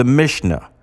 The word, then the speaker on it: Mishnah: So now, if you want to know where I got my information from, go to the Mishnah, Gittin. I'll spell it for you, M-I-S-H-N-A-H. Second word, Gittin, G-I-T-T-I-N.